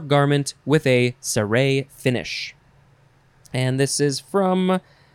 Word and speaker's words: ciré: You have to emphasize the second syllable, ciré, ciré. It is spelled C-I-R-E.